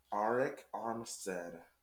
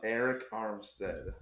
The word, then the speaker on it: Arik Armstead: 21, Arik Armstead. – You can't even say his name, and you're putting him ahead of Bradley Chubb. – Arik Armstead.